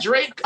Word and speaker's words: Drake: Yeah, Drake. – Oh, the bitch said Durake. Like, bitch. – Yeah, you gotta say the name right.